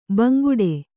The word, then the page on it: ಬಾಂಗಡಿ: kannada; bāngaḍi